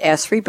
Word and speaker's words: Esri: We'll talk with you and your colleague, Doug, in a few minutes about what you're doing at ESRI. But am I pronouncing it right, ESRI or ESRI? What do you prefer? – No, ESRI is fine. Esri.